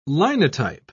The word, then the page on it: linotype: British English